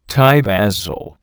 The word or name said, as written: Thai basil